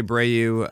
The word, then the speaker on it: Abreu: The other options here, Jose Abreu, I'm pronouncing him right.